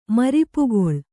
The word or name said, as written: ಮರಿಪುಗೊಳ್